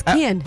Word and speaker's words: Kian: Say it right, it's Kian.